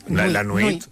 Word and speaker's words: nuit: La nuit.